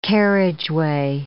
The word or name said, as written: carriageway